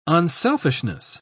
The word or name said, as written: unselfishness